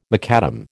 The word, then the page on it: macadam: American English